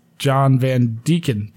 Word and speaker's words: Van Deacon: Oh, and just to clarify, my name is not John Van Dyck. It's John Van Deacon. Deacon is pronounced like deacon, the position one can get at a church.